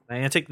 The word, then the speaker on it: Niantic: How do you pronounce the name of the company? – Niantic.